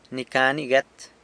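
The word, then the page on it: ni ca ni guet: San Lucas Quiaviní Zapotec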